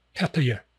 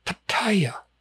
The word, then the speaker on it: Pattaya: Only three syllables. Pretty clear. Pattaya. Okay. I can't count the number of people who will show up there. And some of them have lived there 10 freaking years. And they pronounce it Pattaya. No, it's not fucking Pattaya. Look at the spelling. It has nothing to do with... There's no I in there.